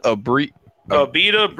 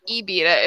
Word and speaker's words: Abita: If it's from Louisiana, it's not Ibita. It's Ibita. – It's Abita.